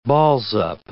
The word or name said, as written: balls-up